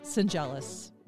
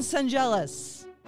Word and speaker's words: Schendzielos: My guest today is Ryan, I'm going to say it right, Schendzielos. Ryan Schendzielos.